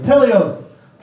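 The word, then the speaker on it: Teleos (τέλειος): I'll tell you how to pronounce it. Teleos.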